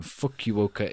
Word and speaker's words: Fukuoka: And then there is obviously World Championships happening in Fukuoka in Japan.